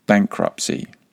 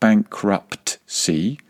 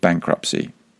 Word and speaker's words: bankruptcy: Repeat after me, bankruptcy. Once again, there's a silent T in there at the end. It is not bankruptcy, it's just bankruptcy.